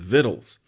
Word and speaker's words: victuals: Did you know that Vittles is spelled V I C T U A L S? There is no word victuals, technically. That word is pronounced victuals and has been for a long time.